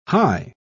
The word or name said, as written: hie